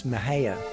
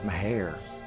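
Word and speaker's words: Mexia: Some of you all may know that as Mahaya. It's pronounced Meharre.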